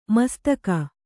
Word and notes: ಮುಸ್ತಕ: mustaka

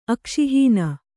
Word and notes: ಅಕ್ಷಿಹೀನ: akṣihīṇa

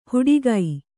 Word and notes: ಹುಡಿಗೈ: huḍigai

